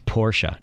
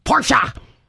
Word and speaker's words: Porsche: But it should be Porsche. And you should pronounce it correctly. – Mm-hmm. – Okay, now this is the one. – That's German, shouldn't you say Porsche? – Porsche.